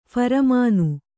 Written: ಫರ್ಮಾನ್

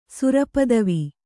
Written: ಸುರಪದವಿ

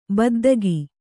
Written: ಬಿದ್ದಿಕ್ಕು

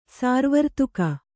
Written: ಸುರವರ್ಧಕಿ

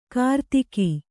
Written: ಕಿರುದೇಗು

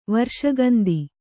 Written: ವರ್ಷಗಂದಿ

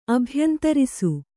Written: ಅಭ್ಯಂತರಿಸು

